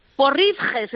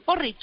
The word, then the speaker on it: porridge: Porridge se escribe, porridge. Como el porrón, pero porridge.